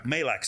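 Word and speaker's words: Majlak: Mike Majerik, you know the guy, the co-host with Mike Malak. – How'd you say it? – Malak. – Sorry, I said his name wrong.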